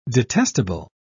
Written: detestable

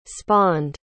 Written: spawned

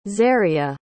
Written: xaria

